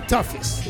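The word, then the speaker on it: Tuffis: But on the estate, on Peter Tosh estate, they call me Tophis.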